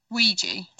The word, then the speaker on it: Ouija: Have you seen the original uh, or, uh, Ouija board uh, movie uh, Oya or how you spell it? Yua. It's O. – Ouija. That's how you spell Ouija.